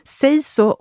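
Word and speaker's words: Seeiso: Han och hans nära vän och kollega prins Seiso av Lesotho. Uttalar jag rätt? – Ja, men det är svårt.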